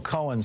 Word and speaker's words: Cohen: Michael Cohen, who was his later lawyer after Roy Cohen died, had Michael Cohen start pronouncing his last name with that single syllable like cone, so that it sounded like he was Roy Cohen.